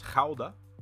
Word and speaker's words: Gouda: Now, you may, of course, as I mentioned earlier, Gouda is the American pronunciation of this. The region or the place in the Netherlands is actually pronounced Gouda. So, I mean, it's going to be pronounced differently. Of course, we just say it differently in America.